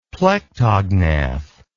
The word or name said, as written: plectognath